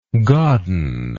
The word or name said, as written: godden